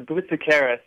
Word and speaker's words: Boutikaris: No, it's Boutikaris. Yeah, it's a Greek name.